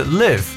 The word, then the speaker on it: live: live L I V E 这个词除了表示动词 live 之外呢，做形容词 live，它的读音表示为现场的，对不对？那这个流媒体就是 streaming，S T R E A M I N G，live streaming 就是大家可以理解为直播。